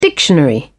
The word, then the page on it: dictionary: British English